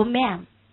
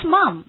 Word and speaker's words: ma'am: 读 ma'am，怎么读 ma'am？ ma'am，好， ma'am just means madam， ma'am 就是 madam 的概念。好，所以我们常常会说，你会听到 yes ma'am，你不要以为他说 yes mom，不是 yes mom，是 yes ma'am，他这个发音不一样。